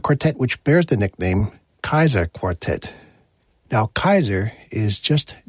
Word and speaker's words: Kaiser: There's no C originally in the German language, so you substitute a K and the pronunciation becomes Kaiser.